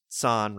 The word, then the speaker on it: San: The story is told partly in flashback to McCoy's past to a mission to the planet San. Pronounced San rhymes with Khan.